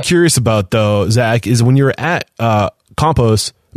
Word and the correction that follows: Campos: am I saying it correctly? Is it Compost? Campos. Okay, yeah. Sorry.